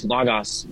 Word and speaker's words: Logos: We use Logos with people who've learned Greek in America because they've most likely learned the Erasmus pronunciation, Logos. But people who haven't taken Greek will think it's L-A-G-A-S, so we say Logos to make it clear it's two O's.